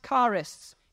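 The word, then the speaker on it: charis (χάρις): Now, the word grace in the original language is pronounced charis.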